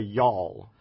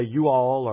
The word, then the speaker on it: y'all: As long as it's a one-syllable word. As I've been trying to teach our children at Emmanuel Lutheran School how to say y'all, they all want to say you all or y'all or, you know, no, it's just one syllable with a drawl, y'all.